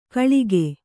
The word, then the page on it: ಕೊಳಕು: koḷaku